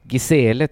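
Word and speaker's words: Gisèle: Gisele tror jag det ska uttalas.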